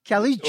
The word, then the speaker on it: Caliche: The Caluche. – Caliche. – Well, I mean, how do you say it? Caliche.